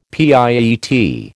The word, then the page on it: pietà: American English